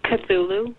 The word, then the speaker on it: Cthulhu: And the Cthulhu V2. – Cthulhu V2. – I like that you pronounce Cthulhu the correct way, by the way.